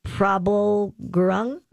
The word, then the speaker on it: Prabal Gurung: Prabal Gurung.